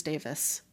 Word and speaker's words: Davies: The Welsh pronunciation of Davies is Davis.